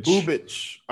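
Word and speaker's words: Bubic: Bubich.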